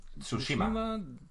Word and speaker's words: Tsushima: ¿Cómo se dice, tío? Ghost of Tsushima... – Tsushima. Tsushima. – Tsushima. Vale, Tsushima. Es que no recuerdo bien.